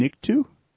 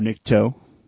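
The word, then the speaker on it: Nikto: It's the Sears exclusive Jabba the Hutt dungeon, complete with three figures, 8D8, Klaatu, and Nictu, or Nicto, depending on how you pronounce it.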